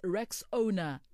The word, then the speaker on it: Rexona: A friend even called it Rexona, but guys, it's Rexona, like Rex and Ona.